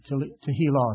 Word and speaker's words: Tehillah: T-E-L-L-H-A-H, I believe. Tehillah is how you pronounce it.